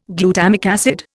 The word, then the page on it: glutamic acid: Indian